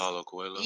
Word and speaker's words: Paulo Coelho: Paulo Coelho.